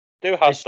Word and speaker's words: Du Hast: No, I went with, is it Du Hast? Is that how you say it? – Yes, Du Hast.